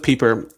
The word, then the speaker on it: Pieper: I think that's how you say his name. It looks like Piper. But I think it's Joseph Pieper.